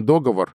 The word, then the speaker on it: договор: Вот мы видим, да, прошло 50 лет, по-прежнему ударение договор а, ходит в таких плохишах у многих носителей языка и объяснить людям, что договор — это, вообще говоря, уже давно допустимый вариант в разговорной речи, до сих пор крайне сложно.